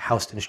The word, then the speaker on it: Houston: It's actually Houston Street, but I figured you'd be distracted by me pronouncing it the right way.